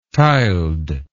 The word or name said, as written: tiled